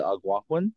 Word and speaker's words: Algonquin: your Brett is like dying to bring me to Algonquin Park. I don't even know how to say it. Algonquin. – Algonquin. – That's it.